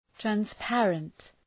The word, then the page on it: transparent: træns’pærənt